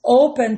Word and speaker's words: open: Open termina em CVC, N consoante, E vogal, P consoante. Ele termina em CVC, mas eu falo open. A sílaba tônica é O.